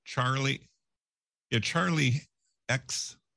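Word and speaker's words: Charli XCX: We have Charlie XCX.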